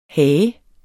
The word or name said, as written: hage